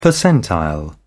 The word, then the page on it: percentile: British English